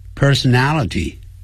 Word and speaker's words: personality: 这种字比较长，那我教大家发音的时候由后往前念 nality nality personality personality，你看马上就会了。